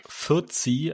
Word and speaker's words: Fuzzy: Eigentlich heißt er Fürzi, also angelehnt auf Fu, Foot, also Fuß.